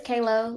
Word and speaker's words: Kaeloe: Yes, it's Kalo, like Halo, but with a K.